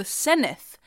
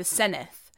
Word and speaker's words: Senedd: or the Senedd, as they call it, to give it its official title, the Senedd. By the way, that's spelled S-E-N-E-D-D.